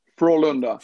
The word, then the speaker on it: Frölunda: Frölunda.